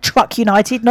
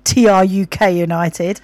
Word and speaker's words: TRUK United: So she actually said it right, Truck United, not TRUK United.